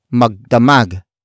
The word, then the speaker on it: magdamag: It is the opposite of magdamag. Magdamag. Magdamag, which is spelled as M-A-G-D-A-M-A-G, which means all night long.